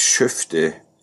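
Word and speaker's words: Skövde: In Sch ich würde es Schöfte aussprechen in Schweden.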